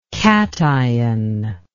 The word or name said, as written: kation